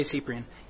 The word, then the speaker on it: Cyprian: So the example I thought of was St. Cyprian, or Cyprian, however you want to pronounce it. I say Cyprian.